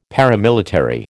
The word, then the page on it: paramilitary: American English